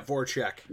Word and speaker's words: Voracek: Vrasic get older. Voracek, is that how you say it? – Yeah, Voracek. Yep.